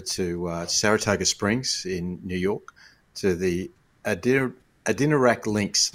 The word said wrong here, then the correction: Adirondack: It's Adirondack. Adirondack, and that's the American lingo on the end of it.